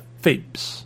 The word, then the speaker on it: Thebes: que en inglés se pronuncia Thebes.